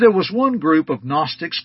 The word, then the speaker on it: Gnostics: Now, that is spelled with a G, G-N-O-S-T-I-C-S. The G is silent.